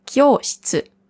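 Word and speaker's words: Kyoshitsu (教室): Kyoshitsu, also nicht shi-tsu, sondern shi-tsu. Das I hört man nicht oder fast nicht.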